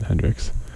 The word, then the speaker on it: HNDRXX: Well, it's spelled H-N-D-R-X-X, but we all know it. You pronounce it Hendrix.